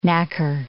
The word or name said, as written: knacker